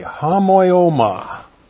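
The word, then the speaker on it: homoioma: Looks like that in the Greek, H-O-M-O-I, long O-M-A, hamoioma. Hamoioma means likeness, and then parabasis. Looks like that in the Greek, P-A-R-A-B-A-S-I-S, parabasis.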